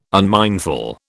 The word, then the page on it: unmindful: British English